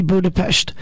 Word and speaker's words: Budapest: Only I'm sure in most theaters they're calling it The Grand Budapest Hotel, but if you were truly pronouncing the name of that city correctly, it would be Budapest.